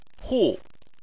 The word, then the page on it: hu: 4